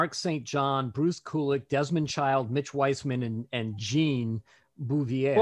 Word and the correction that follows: Beauvoir: Beauvoir. – How do you pronounce the last name? – Beauvoir. – Beauvoir.